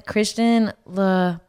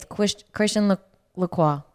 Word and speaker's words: Christian Lacroix: This is a vintage outfit, and it's from, his name is Christian LaCroix. That's how you say it. But it's spelled like LaCroix, like the drink. And it's Christian LaCroix.